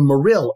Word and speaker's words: Morrill: Hence, I do not want anyone hearing my voice to confuse the name of that non-moral law as if it is supposedly a moral law. So now you know why I will always pronounce it as the Morrill Anti-Bigamy Act of 1862.